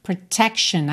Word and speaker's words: protection: Protection, atenção também à pronúncia dessa palavra, porque a gente tem essa terminação, olha, outro sufixo aí, né? T-I-O-N, que a gente não fala chion, chion, tá? Fica shen, shen.